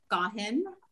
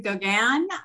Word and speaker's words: Gauguin: So my name is Maura Gauguin. But like I said, if you want to pronounce it Gauguin, that's fine.